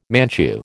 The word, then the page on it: Manchu: American English